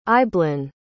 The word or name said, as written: eibhlin